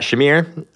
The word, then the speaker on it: Shamir: So we're lucky to have Phil in person here with us, and we've also got Shamir.